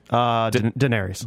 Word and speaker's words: Daenerys: Daenerys.